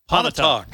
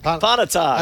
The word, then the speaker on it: Pontotoc: I probably pronounced the county wrong. You guys can correct me. It's like Pontotoc. – Pontotoc. – Pontotoc.